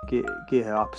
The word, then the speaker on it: Geher: Geher, it's spelled G-E-H-E-R.